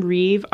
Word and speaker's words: Reeve: Yeah, it's just my first name, Reeve, R-E-E-V-E.